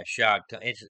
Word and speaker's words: Shad: What you do, I mean, what basically I do if I'm Shad Khan, or how do you say his first name? Is it Shad? Or Yeah, Shad Khan.